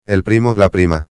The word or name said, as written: el primo/a